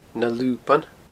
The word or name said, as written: Na Luban